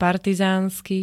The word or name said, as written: partizánsky